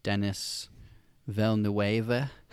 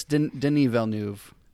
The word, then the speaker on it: Denis Villeneuve: This is a long time coming, this movie, directed by Denis Villeneuve. Is how you say his name. I thought it was like Denis Villeneuve. But it's Denis Villeneuve is how you say it.